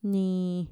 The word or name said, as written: nii